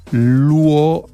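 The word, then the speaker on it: Loueke: Je vais le prononcer comme j'ai appris que c'est Luoek. Il va pas mal sortir. Lionel Luoek. Que j'imagine qu'en francophonie serait Luoek.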